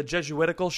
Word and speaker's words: Jesuitical: I am co-hosted with, from the Jesuitical show.